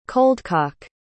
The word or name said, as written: coldcock